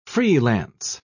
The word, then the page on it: free-lance: British English